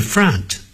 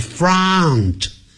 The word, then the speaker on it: front: 我们接着往下看。Instead of getting exercise，他说他没有，非但没有，要 instead of，不要翻成火箭，要非但不，也不要翻成火箭部，更不要翻成国防部，这边叫做非但不。Instead of，非但不运动 getting exercise， they are sitting，他们一天到晚用现在进行时表示常态，随时随地都在坐着，在什么前面呢？In front of，那个 front 不要念成 front，它是倒 v 的那个发音，肚子饿的饿。In front of，